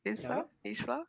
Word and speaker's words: Vizsla: Um, I'm probably gonna say pronounce this wrong. Is it Vizsla. Is it Vizsla? – Yeah. – Yes.